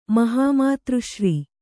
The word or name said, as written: ಮಹಾಮಾತೃಶ್ರೀ